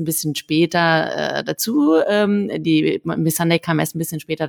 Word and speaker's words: Missandei: Wie spricht man sie aus? Die Freundin von Khaleesi? – Missandei, ja.